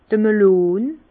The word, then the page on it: d' Melon: Bas Rhin; Herrlisheim